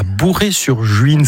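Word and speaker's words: Bouray-sur-Juine: Henri Guibet, aujourd'hui vous vivez à Bourré-sur-Juine. C'est comme ça qu'on prononce l'endroit où vous vivez.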